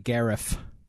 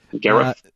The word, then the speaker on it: Gareth: Garrett? Yeah. They just like they eat trout with their bare hands raw. – Gareth Bale. Yeah, you got to pronounce it Gareth. – Gareth.